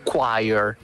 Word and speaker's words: choir: quindi scritto C-H-O-I-R si pronuncia choir, perché?